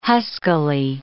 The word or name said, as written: huskily